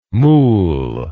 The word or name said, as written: mool